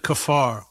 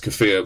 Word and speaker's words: kafir: Is it kafir or is it kafar? Kafar is the word for infidel. – Is well, yeah, maybe I mispronounced it. Yeah, it's kafir.